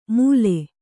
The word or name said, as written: ಮೂಲೆ